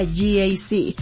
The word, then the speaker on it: GAC: Se escribe GAC, pero se pronuncia GAC. Repítelo, GAC Angelópolis, donde el tráfico termina y el manejo empieza.